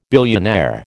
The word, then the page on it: billionaire: American English